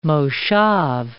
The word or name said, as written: moshav